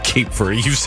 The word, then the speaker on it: Cape Verde: Cape Verde, by the way. Cape Verde. You've said it wrong twice.